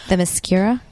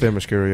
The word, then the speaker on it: Themyscira: Themyscira? – That one. That, yep, yeah, that, yep. That's the word. – Themyscira, yeah.